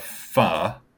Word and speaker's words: pho: Hannah ended up getting the pho, and I'm pronouncing that right? – Correctly. Yes, okay. you are. So uh, uh, when it feels wrong, that I'm saying it right.